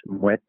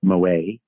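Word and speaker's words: Moët: So for anyone listening out there, it's Muet. It's not Moe.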